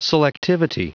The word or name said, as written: selectivity